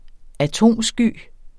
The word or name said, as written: atomsky